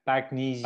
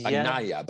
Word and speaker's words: Bagnaia: And then um, uh, back, how do you how, how do you pronounce this in Italian? Back. Bagnaia, Bagnaia, Bagnaia, Bagnaia I think, yeah.